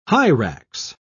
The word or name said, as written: hyrax